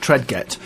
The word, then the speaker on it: Tredget: But it's pronounced Tredget. – Yes, I think it is.